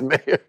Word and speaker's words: Mayer: I knew there was some reason why I didn't like John Mayer. Or is it Mayer? – Yes, Mayer. It's even better.